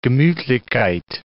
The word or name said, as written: gemutlichkeit